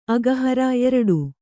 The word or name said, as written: ಅಘಹಾರಿ